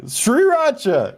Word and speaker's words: Sriracha: It's S-R-I-R. – Yeah. Sriracha.